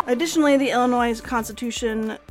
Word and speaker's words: Illinois: When Congress admitted Illinois, oh my god, I always forget how to say it. Illinois. No S.